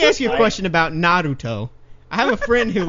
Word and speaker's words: Naruto: Okay, first of all, it's pronounced Naruto, okay? – Oh, whoa.